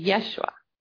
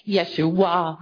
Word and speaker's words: Yeshua: And I want to teach you how to pronounce it because a lot of people pronounce it in many different ways. Like Yeshua, and they have the emphasis on the end of it.